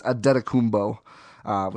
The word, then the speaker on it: Antetokounmpo: And that's how you pronounce his last name, by the way. It's Antetokounmpo, which gets a lot of people, which is fair.